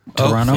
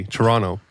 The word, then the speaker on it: Toronto: Toronto? – You got to say it like a local. – All right, teach me. – You just skip the second T. Toronto.